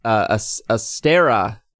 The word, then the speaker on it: Astera: But what we will start off with is Astera. Did I say it right this time? – You did.